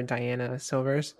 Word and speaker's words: Diana Silvers: The only reason I watched it was for Diana Silvers.